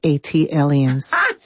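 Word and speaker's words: ATLiens: That is pronounced A-T aliens. – Thank you. – Like Atlanta alien. A-T alien.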